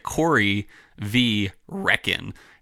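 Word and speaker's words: Corryvreckan: concerning this bottle, though, Korvrekkvr, that's spelled Korryvrekkan. So it's like Corey V. Reckon.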